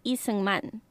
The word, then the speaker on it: Lee Seung-man: In Korean, Syngman Rhee is pronounced differently. What many say as Rhee is actually the last name Lee. In Korean, you'd actually say Lee Seung-man.